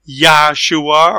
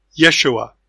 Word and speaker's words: Yeshua: It's not Yahshua or Yahoshua. It's Yeshua.